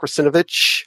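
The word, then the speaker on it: Russinovich: So the malware hunting with SysTools by Mark Rusinovich. Did I pronounce his name right? – Yep.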